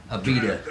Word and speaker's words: Abita: Abita.